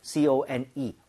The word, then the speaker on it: cone: 근데 우리가 말하는 그콘 모양을 얘기를 할 때는 CONE, R 사운드가 아예 없이 깔끔하게 네.